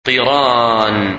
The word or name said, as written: Qiran